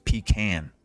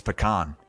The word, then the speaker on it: pecan: I'm surprised you don't pronounce it pecan pie. It's pecan. I didn't have it until I went and visited my wife's grandparents down in Oklahoma when I was about 25, but it's great.